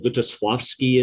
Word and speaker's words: Lutosławski: Witold Lutosławski is another, because the L is actually pronounced more like a W in his last name.